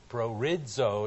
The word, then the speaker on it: proorizo: P-R-O-O-R-I-Z-O, or prorizo, it's pronounced, means to determine before, to ordain, to predestinate.